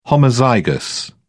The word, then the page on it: homozygous: British English